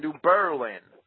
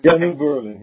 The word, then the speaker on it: New Berlin: And up here they insist you pronounce it New Berlin. – New Berlin. Yeah. – They try to make it not sound like Berlin, you know.